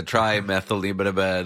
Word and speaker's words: trimethylamine: All right, so you've got the trimethyl oxide, and then you got the trimethylamine, the breakdown chemical that smells fishy. – Yes. – I pronounced that properly, I'm sure. – Absolutely nailed it.